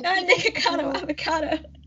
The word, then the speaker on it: Nikocado Avocado: Yeah, like Nikocado Avocado.